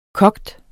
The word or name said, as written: koge